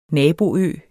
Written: naboø

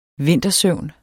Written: vintersøvn

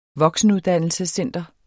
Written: voksenuddannelsescenter

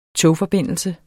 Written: togforbindelse

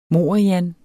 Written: morian

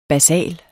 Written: basal

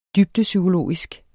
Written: dybdepsykologisk

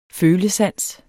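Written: FØLESANS

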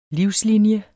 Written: livslinie

